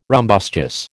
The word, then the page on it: rumbustious: American English